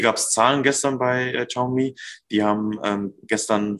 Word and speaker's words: Xiaomi: Auf Xiaomi wird es ausgesprochen, geschrieben wird es Xiaomi.